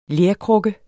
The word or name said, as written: lerkrukke